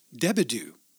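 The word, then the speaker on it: DeBordieu: where the name is now pronounced Debedieu.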